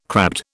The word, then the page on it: crabbed: British English